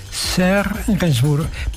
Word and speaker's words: Serge Gainsbourg: ¿Lo pronuncio bien? Serge Gainsbourg, perfecto.